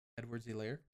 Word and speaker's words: Edwards-Helaire: Edwards-Helaire.